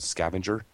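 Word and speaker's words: SCVNGR: He's a Boston native, started a company or is the head developer for a company called Scavenger. I believe that's how you pronounce it. It's S-C-V-N-G-R. It's one of those Web 2.0 names that don't have vowels.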